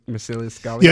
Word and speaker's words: Marcellus Gallio: Am I pronouncing his name correctly? I believe Marcellus Gallio. – Yeah, no, he's not a real historical figure. He was created for the movie, but you are pronouncing it the way they pronounced it in the movie.